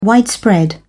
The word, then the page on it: widespread: British English